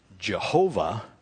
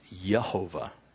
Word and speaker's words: Jehovah: So when you say Jehovah, it would more likely be pronounced Yehovah is the way it would be pronounced.